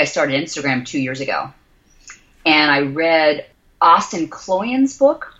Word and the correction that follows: Kleon: Kleon? I think that's how you say it. Kleon, yeah.